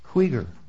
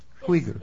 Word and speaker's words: Krieger: Krieger. – Krieger. – Krieger. It's like K-R-E-E-G-E-R, sir. – Okay. – So Krieger.